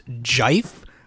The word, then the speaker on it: GIF: It's actually pronounced Jife. But that's horrible. So everybody says GIF.